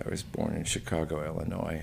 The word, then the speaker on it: Illinois: You know how you can tell someone who's not from Illinois? – How? – They pronounce it Illinois.